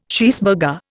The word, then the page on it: cheeseburger: Indian